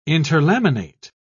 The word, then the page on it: interlaminate: British English